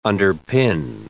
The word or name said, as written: underpin